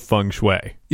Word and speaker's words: Feng Shui: And we pronounced it exactly like that feng shui, the same way that you would pronounce the geomantic exercise of finding harmony in spaces.